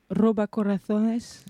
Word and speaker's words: Roba Corazones: Tout à fait, de Pere Jorge, Roba Corazones. – Tu le prononces très bien.